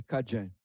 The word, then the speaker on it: Cadien: Ça fait qu'on a resté avec, on s'appelle, nous sommes des Cajuns. Ça s'écrit C-A-D-I-E-N et ça se prononce C-A-D-J-I-N, Cajun.